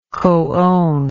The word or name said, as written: coown